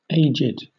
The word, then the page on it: aged: British English